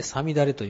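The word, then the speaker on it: 五月雨: で、さつき雨と書いてさみだれという、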